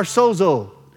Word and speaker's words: sozo: That means God is our salvation, that God is our sozo. Sozo, S-O-Z-O, but it's S-O-D-Z-O is how you pronounce it.